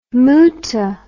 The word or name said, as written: mutah